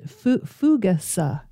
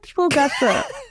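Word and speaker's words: fougasse: Fugas was my guess as a person who does sort of speak French, but the little caption in here does say it's pronounced fugasa. – Okay. Let's go with. – Isn't that wild? – Let's go with fugasa.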